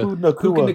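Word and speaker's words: Nacua: How do you say his name? – Puka. – Nakua. – Nakua. – Pupu Nakua.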